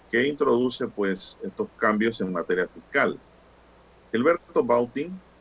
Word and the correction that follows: Bután: o Bután, Gilberto Bután, así se debe pronunciar el nombre, el apellido del profesor,